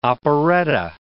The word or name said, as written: operetta